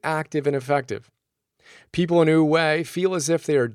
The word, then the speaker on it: Wu Wei: Our first big idea is Wu Wei, which is spelled W-U-W-E-I.